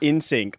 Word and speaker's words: NSYNC: So it's pronounced NSYNC, like the word, because it actually came from Justin Timberlake's mum.